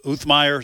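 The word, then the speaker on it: James Uthmeier: And Attorney General Uthmeyer, is that how you pronounce it? – I think so. I've heard it several ways. That's how I pronounce it. So we'll go with that.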